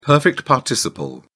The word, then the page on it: perfect participle: British English